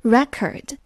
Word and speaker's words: record: 下面这个词呢有两个发音，一个是它作为形容词和名词的时候读作 record，做名词的时候表示记录，相对应形容词的意思就是创记录的。它做动词的发音我们会更熟悉，读作 record。